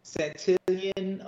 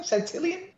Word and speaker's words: Santillan: Santillan. How do you say his last name? Santillan? – Yeah, Santillan. – Or is it Santillan? – Well, you're right. San. – You know, he's got the double L.